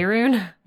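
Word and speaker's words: Faerûn: Faerun? – Yes, actually.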